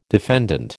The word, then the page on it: defendant: American English